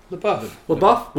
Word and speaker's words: LaBeouf: LaBeouf? How do you say that? LaBeouf? LaBeouf? – LaBeouf. – We'll go with LaBeouf.